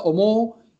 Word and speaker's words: omou: Que es una O larguita, omou, y se escribe omou, y él le coloca que es yodan, que es pensar, y que también está bien.